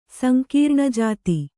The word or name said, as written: ಸಂಕೀರ್ಣಜಾತಿ